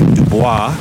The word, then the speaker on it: Dubois: Now, Du Bois is spelled D-U-B-O-I-S, so when you look at it, it looks like Du Bois, but the people in town voted to have the official pronunciation be Du Bois,